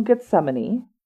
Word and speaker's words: Gethsemane: So, olive leaves from Gethsemane.